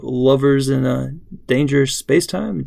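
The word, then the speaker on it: Lovers in a Dangerous Spacetime: folks can let us know if they know less about Leet Speak, and if they have any wonderful things to add to Lovers in a Dangerous Space-Time.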